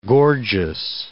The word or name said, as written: gorgeous